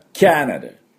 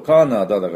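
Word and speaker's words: Canada: カナダ、これも、多少ネイティブの発音と違います。まあカナダって言って通じないことはないでしょうけども、実際はキャナダ、キャナダっていうふうに発音します。キャ、 CA のところがですね、おミャーの音なんですね。でキャナダで、そこにアクセントがあります。キャナダでなくてですね、キャナダ頭にあるんですね。こう 3発作るとカナダだからカナダって言いそうな感じですけども、実際はキャナダ、キャナダ。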